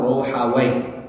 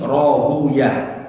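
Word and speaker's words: Rohuyah (راهويه): Nah, menurut al-Imam Ishaq bin Ruhawaih, ya, Ruhawaih itu bisa dibaca dua, ya, Rohuyah atau Ruhawaih. Kalau di sini Rohuyah, kebanyakan ulama al-hadis membacanya Rohuyah. Kenapa? Karena Waihin itu nama setan atau nama iblis. Sehingga mereka tidak suka untuk menyebutkannya.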